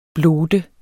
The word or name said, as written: blot